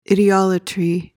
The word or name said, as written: idiolatry